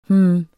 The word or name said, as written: hm